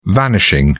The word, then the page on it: vanishing: British English